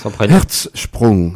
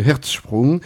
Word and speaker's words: Hertzsprung: Hertzsprung, voilà. – Hein, Hertz comme les voitures, comme voilà. Le cœur en allemand, et Sprung comme le saut en allemand. Donc le saut du cœur, monsieur Hertzsprung.